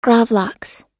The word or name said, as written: gravlax